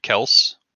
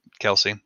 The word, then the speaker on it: Kelce: Mahomes decided not to throw the ball to Kelce. – Mm-hmm. Kelce.